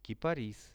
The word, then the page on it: kiparis: Russian